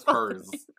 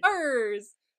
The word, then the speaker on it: Pirz: It spelled as Purrs. – Purrs. – P-I-R-Z. – Purrs!